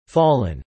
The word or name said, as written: fallen